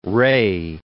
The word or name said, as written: Rae